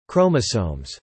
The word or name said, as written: chromosomes